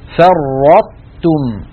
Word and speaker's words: فرطتم: لذلك نطق هذه الكلمة كما فعلت وفعل من وراء إخواننا بارك الله فيهم أن ننطق هكذا فرطتم فرطتم، فنطبق نقرع بطرف لساننا مخرج الطاء من غير قلقلة، ثم نباعد هذا الالتصاق عن تاء، نطبق على طاء غير مقلقلة ونباعد اللسان عن تاء مرققة.